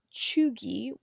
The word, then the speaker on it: cheugy: In May 2021, the phrase choogy, which is spelled C-H-E-U-G-Y, really entered our lexicon and took over the internet.